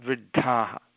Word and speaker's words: वृद्धाः: When you pronounce that D, there should be increased air coming out of your mouth. Dha, dha, vriddhaha.